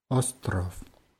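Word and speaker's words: остров: Ja, wird mit zwei O's geschrieben und mit W am Ende, aber dieses W wird wie F eher ausgesprochen. Ostrov. – Ostrov. – Und das zweite O wird eher so ein bisschen verschluckt. Ostrov.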